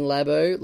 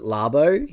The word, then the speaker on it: Labo: Brod, I'm very sorry that I pronounced it Labo. He was very upset at that. – Apparently, it's Labo. Okay, so from now on, I will refer to it as Labo.